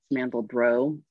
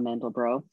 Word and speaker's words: Mandelbrot: or the I always say it wrong because it's French. It's Mandelbrot, and you know, Benoit Mandelbrot.